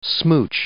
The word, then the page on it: smooch: noun